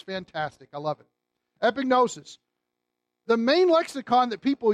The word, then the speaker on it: epignosis: For the longest time, I said epignosis, and then I asked somebody who knew Greek to pronounce it for me, and they said epignosis. So just think of a pig in the middle of it. You won't go wrong in pronouncing it.